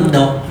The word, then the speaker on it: ndok: Kalau bahasa Sedanau itu bilang tidak itu ndok gitu.